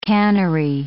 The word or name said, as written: cannery